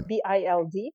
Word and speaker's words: Bild: And it was a doll made after a comic book like persona was named Lily, and it was in the newspaper Bild. This newspaper still exists, and it's like Bild Lily, but Bild not spelled like Bild Building, but right. Bild.